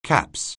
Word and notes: caps: anglais

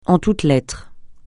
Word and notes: en toutes lettres: français

